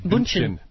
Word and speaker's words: Bündchen: Bündchen. Bündchen. With an umlaut. – Well, sure. Yeah, but sure. Americans say Bündchen.